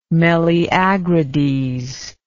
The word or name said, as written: meleagrides